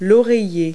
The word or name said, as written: pillow